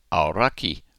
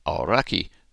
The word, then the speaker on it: Aoraki: and in Maori is spelt A-O-R-A-K-I, and is pronounced Auraki. Now, if we were to fully anglicise that, it would be Auraki, and that may be how some people pronounce it, but newsreaders, for example, on New Zealand television are encouraged to pronounce it Auraki, that is, to get closer to the Maori pronunciation.